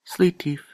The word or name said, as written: slitif